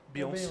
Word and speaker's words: Beyoncé: Quer dizer, qual é a pronúncia certa? Beyoncé? – Beyoncé. Beyoncé.